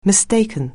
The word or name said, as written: mistake